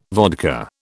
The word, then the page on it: vodka: British English